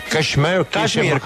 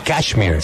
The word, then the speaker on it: Cashmere: Uno que no sé cómo se pronuncia. Cashmere, como la canción de Led Zeppelin. – Ah, Cashmere.